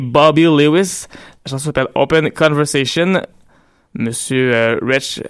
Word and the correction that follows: Wretch: 32, en fait, Wretch 32, c'est comme ça que lui veut qu'on le prononce, vient de Tottenham.